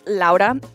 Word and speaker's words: Laura: It's pronounced Lauda, but it's spelled L-A-U-R-A, like Laura.